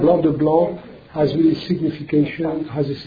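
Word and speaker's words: Blanc de Blancs: You pronounce it Blanc de Blanc, but in French we say Blanc de Blanc.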